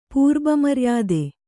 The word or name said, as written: ಪೂರ್ಬಮರ್ಯಾದೆ